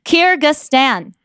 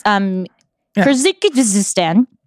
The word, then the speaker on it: Kyrgyzstan: But that's a song from a kids YouTuber called D Billions, which is from, I actually did look this up, how to pronounce it, Kyrgyzstan. That's how you pronounce Kyrgyzstan.